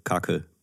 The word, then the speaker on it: Kaki: Kaki wird gar nicht, äh, gar nicht Kaki ausgesprochen, sondern äh, Kake. Also wie mit, Kaki mit dem klingt süßer. Toi! Ja, mit dem mit diesem äh, türkischen i ohne Punkt Kake.